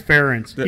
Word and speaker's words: Ferentz: He's Ference.